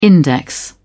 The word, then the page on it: index: British English